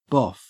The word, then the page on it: boff: Australian English